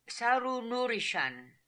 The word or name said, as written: Saroonoorishan